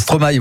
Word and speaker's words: Stromae: Stromae, ou faut dire Stromae, hein, c'est Émilie de Kegel. À chaque fois, elle me dit, mais arrête de dire Stromae, c'est Stromae, voilà, ça se prononce en Belgique avec Pomme, ma meilleure ennemie.